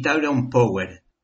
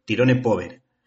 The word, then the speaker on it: Tyrone Power: aunque nuestras abuelas lo pronunciaban tal y como lo leían, es decir, tirone pover,